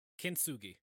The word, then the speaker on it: kintsugi: It is known as a kintsugi. I'm gonna butcher it. – Yeah. – Kintsugi.